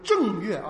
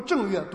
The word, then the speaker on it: 正月: 刚才这个弟兄读正月啊，这个不读正月，读正月啊，这个是一声啊，正月就表示是第一个月啊，这个多音字啊，这个正月。